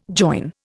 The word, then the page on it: join: Indian